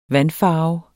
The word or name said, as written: vandfarve